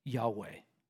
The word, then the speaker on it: Yahweh: Or we can best figure pronounced like this, Yahweh.